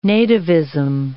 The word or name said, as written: nativism